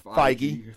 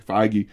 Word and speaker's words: Feige: But here's my question is, you know, we've talked about this, I think, in previous podcasts, is Kevin, and I'm going to mispronounce his name, Feige. – Feige. – Feige.